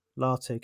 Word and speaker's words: Latu: The final tight end that's come off the board in round three, pick 101, Cameron, is it Latu or Latu? How are we pronouncing this? – Latu, Cameron Latu.